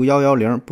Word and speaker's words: 幺幺零: 呃，这个幺幺零也好，幺幺九幺幺九也好，为什么这里边的一读成幺啊？这个呢，主要还是借鉴了部队当中的这种读法。